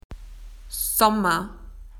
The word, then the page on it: summer: Australian English